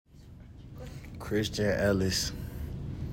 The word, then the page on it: Christian Ellis: KRIS chin EL iss